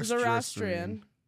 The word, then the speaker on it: Zoroastrian: Zoroastrian.